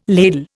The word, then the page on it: ladle: Indian